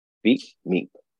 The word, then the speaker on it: Veep Meep: It is spelled V-E-E-P. That's Veep. And Meep is M-E-E-P. Veep Meep.